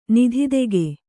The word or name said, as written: ನಿಧಿತೆಗೆ